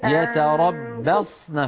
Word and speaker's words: يتربصن: والمطلقات يتربصن يتربصن فخم الصاد.